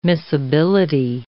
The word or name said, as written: miscibility